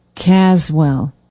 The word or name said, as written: caswell